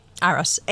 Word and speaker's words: Arras: I'm so sorry, but Arras, France. Arras, A-R-R-A-S, Arras.